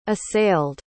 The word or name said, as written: assailed